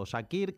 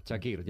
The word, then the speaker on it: Çakır: que le han llamado de todo. Shakir, Kakir, Zakir. Bueno, pues el propio árbitro del partido, escúchenle, porque es él. La voz de el colegiado turco que pita esta noche, así se pronuncia. – My name is Cüneyt Çakır. – A ver, otra vez, por favor. – My name is Cüneyt Çakır. – Pues ahí está, Çakır, ya lo sabes.